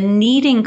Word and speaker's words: Kneading: And uh, something I meant to mention earlier in the bio, the Kneading Conference, if anyone's curious about that, is spelled K N E A D I N G, like kneading bread, which is very clever.